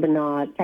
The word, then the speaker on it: Bernard: And Bernard is spelled B-E-R-N as in Nancy, O-D as in David.